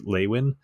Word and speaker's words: Lewin: This is a Lewin Diaz, and it is Lewin. It's spelled Lewin, but pronounced Lewin.